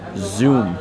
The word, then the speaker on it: Dung: It's spelled Dung, as in D-U-N-G. Dung. That's her name. Dung. But it's actually not her name. If memory serves me correctly, Dung is pronounced Zoom, which is like Z-O-O-M.